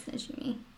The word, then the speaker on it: Najimy: And it stars Bette Midler, Sarah Jessica Parker, and Kathy Najimy. I hope I'm saying that right. – That's how I thought it was pronounced, too. – Okay. – That's Najimy.